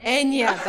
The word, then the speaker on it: Enya: Enya, that's how you say it.